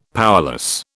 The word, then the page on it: powerless: British English